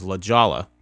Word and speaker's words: La Jolla: It's the opposite of California, where you don't pronounce it how it sounds. – Yeah. – Like La Jolla is La Jolla.